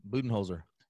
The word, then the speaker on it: Budenholzer: Budenholzer.